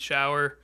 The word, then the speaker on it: Schauer: You can find me on LinkedIn, Pete Schauer.